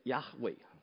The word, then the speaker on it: Yahweh: 这个字真正应该念的是 Yahweh，但是犹太人到今天不敢念这个字。